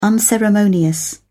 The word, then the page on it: unceremonious: British English